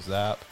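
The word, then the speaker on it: Zappe: I really like Bailey Zapp. That's how you pronounce his last name.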